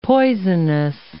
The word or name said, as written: poisonous